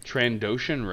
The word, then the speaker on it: Trandoshan: I think we labeled Tardosian Rage here, but I thought I kind of prefer the wait, what'd you call it? – Trandoshan Rage. – Okay. – Did I say it wrong? – You I did say something different.